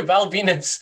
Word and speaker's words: Val Venis: Val Venis, the guy, the wrestler who came out to a tell with a gimmick of a porn star whose name rhymes with penis, will not be in this year's Royal Rumble.